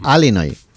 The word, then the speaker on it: Illinois: Se pronuncia Illinois. – Sí, perdón, perdón, sí. – Illinois. – Pues yo digo Illinois, si no, entendemos todos. Porque como diga Illinois, nadie lo entiende.